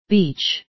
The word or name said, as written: beach